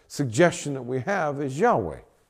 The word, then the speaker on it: Yahweh: It's not Jehovah. It can't grammatically be three syllables. Jehovah. That's three syllables. Syllables. Three. You can't have three syllables. It's improper Hebrew. But they did the best they could. But it's still improper Hebrew to translate this into Jehovah. It's Yahweh. It's only two syllables.